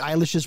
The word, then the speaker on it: Eilish: you're going to be talking about Billie Eilish's performance. It's Eilish. Or spell it E-Y-E-L-I-S-H. – Right. – Spell it phonetically so she doesn't say Eilish.